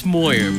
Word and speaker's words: Moyer: It's Moyer, but you're close enough. – Joe Moyer. – Yeah, you're close enough. – Like Foyer, Joe Moyer.